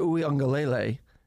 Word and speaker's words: Uiagalelei: They have a quarterback, DJ Uiagalelei.